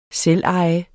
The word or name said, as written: selveje